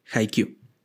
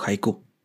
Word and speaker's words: Haikyuu: Entonces hagan de cuenta que el primero se llama Haikyuu o Haiku. Cualquiera de las dos pronunciaciones sirven.